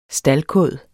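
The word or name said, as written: STALDKÅD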